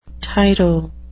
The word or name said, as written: tyro